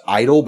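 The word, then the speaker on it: (G)I-DLE: The other one that I will give you is a group called, the name, it's just pronounced Idol, but if you're looking for it, look for G-Idol.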